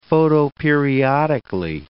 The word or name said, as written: photoperiodically